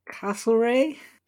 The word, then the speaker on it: Castlereagh: Castle. R-E-A-G-H. – Uh-huh. – How would you say that? – Castlereagh? – Yeah, that did sound like more of a name.